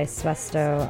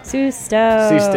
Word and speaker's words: Susto: Before that, we listened to a song called Dream Girl that was self-released by Suesto on their album Suesto. – Susto.